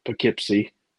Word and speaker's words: Poughkeepsie: Poughkeepsie, you know, if you will.